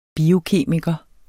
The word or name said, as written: biokemiker